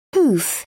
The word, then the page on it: pouf: British English